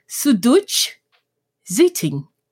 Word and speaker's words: Süddeutsche Zeitung: and I'm not going to get this pronunciation right, but it's a German publication. I'll give it a go called Süddeutsche Zeitung. – That sounds right to me.